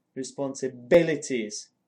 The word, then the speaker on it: responsibilities: It was the plural of the word responsibilities, and for some reason I didn't place the stress where it belongs, which is responsibilities.